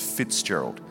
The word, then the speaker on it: Fitzgerald: When I was a kid, my last name rhymes with schizophrenia. It's Fitzgerald.